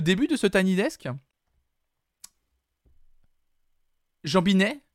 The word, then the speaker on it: Jambinai: Alors j'espère que je prononce bien. J-A-M-B-I-N-A-I. Donc je dirais plutôt Jean Binet.